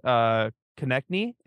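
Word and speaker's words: Konecny: And I was like kind of looking over the stats, and tell me why Travis Konechny, is that how you say his last name? – Good job. Nice job. Good pronunciation.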